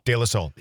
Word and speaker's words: De La Soul: De La Soul.